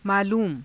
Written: maaluuma

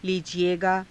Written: liijiegaa

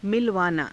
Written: milavaanaa

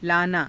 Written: laanaa

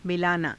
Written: milaanaa

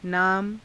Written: naama

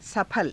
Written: saphala

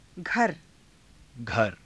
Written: ghara

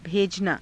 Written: bhejanaa

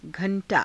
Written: gha^_taa

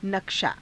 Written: nakshaa

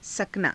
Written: sakanaa

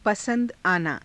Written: pasa^da aanaa

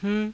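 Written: हूँ

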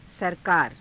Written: sarakaara

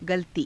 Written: galatii

